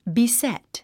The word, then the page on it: beset: bisét; ビセト